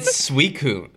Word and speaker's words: Suicune: Is it Suicune? – Oh, yes. Somebody told me it's Suicune. Exactly, bitch. – I've been saying Suicune.